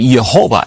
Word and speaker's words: Jehovah: The Hebrews did not have the J sound. So some people say Jehovah instead.